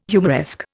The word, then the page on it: humoresque: Indian